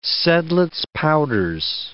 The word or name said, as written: Seidlitz powders